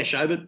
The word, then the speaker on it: Schobert: Yeah, Schobert.